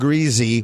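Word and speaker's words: Griese: His last name is pronounced Greasy.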